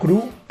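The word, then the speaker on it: Krü: Te traigo una banda que se llama Kru, K-R-U con diéresis. Si fuese en alemán se pronunciaría Kru. Es la U francesa, que pones la boca como para decir U, pero dices I, U.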